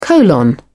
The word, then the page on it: colon: British English